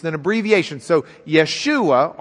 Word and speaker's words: Yeshua: Now, if we were pronouncing it as Hebrews, the Hebrews didn't pronounce the J. It was a yuh sound to them. So it'd be Yeshua instead of Joshua.